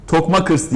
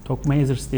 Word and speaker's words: Tokmakers: Tokmakers diye okunuyormuş takım tamam. – ismi. Evet, teşekkür ederiz. Son takımımız. – Ben anlamını merak ettim de. Neyse. – Evet, anlamı da gelir şimdi. – Tokmazers diye okudum.